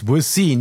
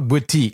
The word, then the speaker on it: Boétie: It's Boétie, not Boétie. – Well, no, it could be either Boétie or Boétie. Okay. And so I've actually had, different actual French citizens tell me both ways.